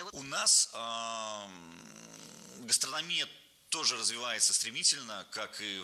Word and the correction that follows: гастрономия: Не гастрономия, а гастрономия. Ударение в этом слове падает на третий слог. Для удобства запоминания есть однокоренное существительное. Гастроном. Гастроном, гастрономия.